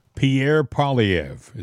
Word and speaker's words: Pierre Poilievre: Pierre Poilievre, is that's how he mm.